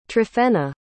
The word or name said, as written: tryphena